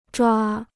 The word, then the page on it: 抓: zhuā